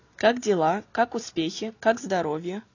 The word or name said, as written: Как дела